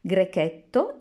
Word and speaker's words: Grechetto: Grechetto is a family of grape varieties thought to have originated in Greece. Some local varieties are Grechetto di Todi and Grechetto di Orvieto.